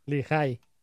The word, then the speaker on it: Lehigh: Está, ¿cómo, se, ¿Cómo dijo que se pronunciaba? ¿Lehigh, Utah? Lehigh, ya, Lehigh. A ver, si ¿sí es Lehigh, Utah.